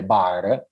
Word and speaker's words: Barres: Un altro film molto interessante e divertente è Bar, purtroppo io l'ho Moscia, cioè, che va incontro un po' al francese, ma eh, il film si scrive Barres,